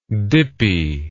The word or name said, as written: dippiness